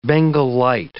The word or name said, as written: Bengal light